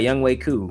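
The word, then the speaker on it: Younghoe Koo: Why score touchdowns when you got Young Wei Koo, right? That's you know, that's how you're supposed to say his name.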